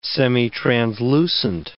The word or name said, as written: semitranslucent